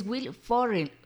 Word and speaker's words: Will Ferrell: Él es Will Ferrell, ya lo dije bien, cumple 59 años.